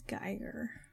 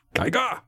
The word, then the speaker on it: Geiger: Is the I first or second? – Second. – It's Geiger. – Well, According said, to the German pronunciation. – Geiger.